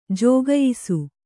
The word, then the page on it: ಜುಕಾಯಿಸು: jukāyisu